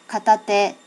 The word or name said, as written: katate